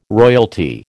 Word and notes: royalty: American English

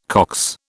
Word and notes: Cox: British English

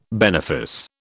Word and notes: benefice: British English

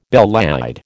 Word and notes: belle laide: American English

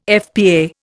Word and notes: FPA: Indian

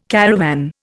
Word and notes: caravan: Indian

